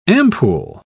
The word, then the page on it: ampule: British English